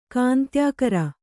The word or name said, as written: ಕಾಂತ್ಯಾಕರ